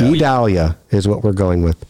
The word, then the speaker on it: Idalia: And we want to naturally say Vidalia, so yes. Idalia. It's kind of like how we want to say it, because you just take the V off of our town and our inner areas. But no, that is not how it is phonetically put out for us.